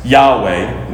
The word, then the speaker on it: Yahweh: We've added the vowels to pronounce it as Yahweh, which I'll explain again when we get to not taking the Lord's name in vain of why we did that.